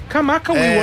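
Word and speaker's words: Kamaka Wiwole (Kamakawiwoʻole): Kamaka Wiwole. Avevo il dubbio sulla pronuncia, l'ho sempre Israel avuto, Kamaka sì. Wiwole.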